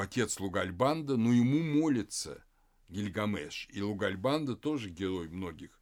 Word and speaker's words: Гильгамеш: Потом это длинное имя редуцируется на просто Бильгамес, Бильгамес, или затем Гильгамеш, уже в акадском произношении.